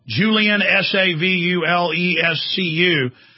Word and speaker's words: Savulescu: And that's the same thing as the other bioethicist at Oxford, Julian Savulescu. Am I pronouncing that right? Yeah, Julian Savulescu.